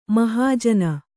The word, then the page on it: ಮಾಹಜ್ಜನ: māhajjana